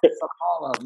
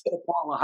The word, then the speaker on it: Sapaula: Sapaula, Matt Sapaula.